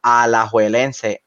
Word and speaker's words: Alajuelense: Hoy vamos a estar hablando acerca del partido que tuvimos con Liga Deportiva Alajuelense.